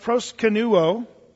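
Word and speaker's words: proskuneo: When you look up the word worship in the New Testament, in the New Testament Greek, the word worship, it's pronounced proskenuo in the Greek.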